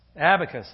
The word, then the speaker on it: abacus: Acabus is the little bead thing, right? Abacus. There we go. Can't even say it right.